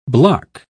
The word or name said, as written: block